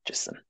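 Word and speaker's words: Jičín: J-I-C-I-N. Jicin.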